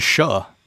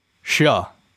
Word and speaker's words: Xia: That's not how you pronounce that. It's Shuh. Shuh. That's how you pronounce it. – That's the X is a SH sound, and yeah. Should be Shuh.